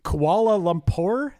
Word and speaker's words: Kuala Lumpur: Dude, I watched her play I Love You Always Forever at the Commonwealth Games in Kuala Lumpur. Is that how you say it? In Malaysia? – Yeah.